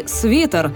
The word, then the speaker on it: свитер: Возможно, для кого-то будет открытием, но в словарях зафиксировано произношение «бутерброд» и «свитер», а не «бутерброд» и «свитер». Нормой считается твердый «т» в словах «террор», «декан», «дефис», объясняет создатель канала «Лингвошутки» редактор Лена Лукьянова.